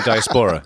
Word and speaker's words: diaspora: You mean diaspora? – Thank you.